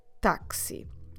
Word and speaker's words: táxi: it's the exact same word and the pronunciation that doesn't change that much. So the taxi is u taxi. Just a bit of a kind of a different A sound. We have a ta táxi.